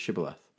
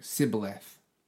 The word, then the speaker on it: Shibboleth: Yeah, they couldn't do the sound sh. So it always come out as s. So like Shibboleth. – They're like Shibboleth.